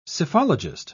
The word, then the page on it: psephologist: British English